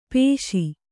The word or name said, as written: ಪಾಶಿ